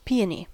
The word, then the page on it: peony: American English